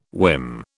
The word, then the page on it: whim: British English